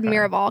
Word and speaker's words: Miraval: And there's another one, but this one's called Miraval, because I called it Marival, and you're like, that's not how you say it.